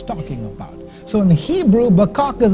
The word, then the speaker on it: baqaq: Verse 1, the word empty, the Hebrew word is bakak, B-A-Q-A-Q.